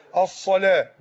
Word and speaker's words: الصلاة: أين هي تنطق هكذا، يعني بعد الصلاة بعد اللام في ألف مد، مش موجود في المصحف ألف مد، وإنما موجود نبرة كده بين اللام والواو، يعني تشير إليك أن هذه ألف مد.